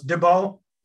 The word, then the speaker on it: Dubeau: Pretty close. Dubeau.